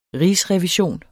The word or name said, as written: rigsrevision